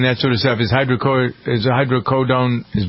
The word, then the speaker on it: hydrocodone: Agency says hydrocodone.